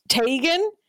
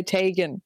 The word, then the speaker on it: Teigen: Her surname is actually pronounced Teigen, but she prefers the incorrect pronunciation. But her family would say Teigen.